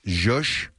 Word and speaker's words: zhuzh: She does that kind of irritating, and then she paused and says she's trying to zhup up the public to listen to her. – Uh-huh. – And that's great. It's zhup, there it is. – Well, it's spelled Z-H-U-Z-H. Zhush. Zhush up the public.